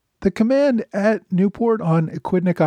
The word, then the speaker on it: Aquidneck: This week, I went over the raids that British General Robert Pigot ordered against the rebel forces surrounding Aquidneck Island. And I've been told numerous times that I've been mispronouncing Aquidneck, Equidneck Island.